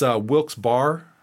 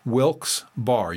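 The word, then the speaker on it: Wilkes-Barre: That's Wilkes Bar. I believe that's how it's pronounced. Wilkes Bar.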